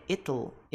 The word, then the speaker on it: it'll: Muito, é a mais curiosa, porque muita gente não sabe como pronunciar a contração de it will. Como é que é? It'll. It'll. Como é que é? Será? A pronúncia americana é it'll.